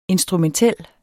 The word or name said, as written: instrumentel